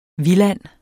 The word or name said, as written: VILDANDEN